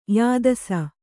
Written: ಯಾದಸ

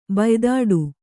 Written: ಬಾಯಿತೀಟೆ

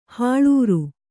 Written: ಹಳುರೆ

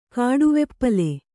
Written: ಕಾಡುವೆಪ್ಪಲೆ

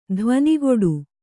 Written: ಧ್ವನಿಗೊಡು

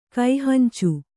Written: ಕೈಹಂಚು

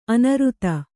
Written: ಅನೂರಾಧ

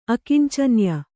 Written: ಅಕಿಂಚನ್ಯ

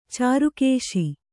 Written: ಚಾರುಕೇಶಿ

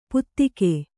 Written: ಪುತ್ತಿಕೆ

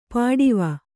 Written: ಪಾಟವ

